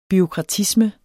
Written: bureaukratisme

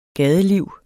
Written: gadeliv

